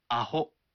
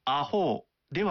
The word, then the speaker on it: アホ: これはアホであってアホーではない。